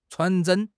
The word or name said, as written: chuan2 zhen1